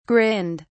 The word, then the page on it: grinned: British English